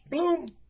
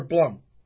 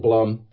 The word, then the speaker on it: Blum: Then Tom Blum or Blum? – Either one. I pronounce it Blum. – Okay. – Like Plum.